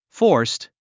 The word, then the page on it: forced: fɔ’ː r st; フォースト